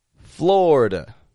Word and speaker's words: Florida: That I in the middle, totally dropping out. Instead of a Florida, just two quick syllables. Florida. Florida. And that A on the ending is a schwa. You want to remember that. That's a schwa. It's not Florida or Florida. But Florida, D, schwa ending.